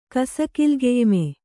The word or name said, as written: ಕಸಕಿಲ್ಗೆಯ್ಮೆ